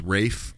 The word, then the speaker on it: Ralph: It's actually pronounced it differently than most people, but it's Rafe Fiennes. – Why is there an L there? – It's spelled like Ralph, but it's pronounced Rafe.